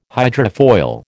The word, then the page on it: hydrofoil: American English